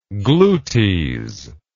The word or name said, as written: glutes